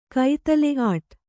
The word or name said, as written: ಕೈತಲೆ-ಆಟ್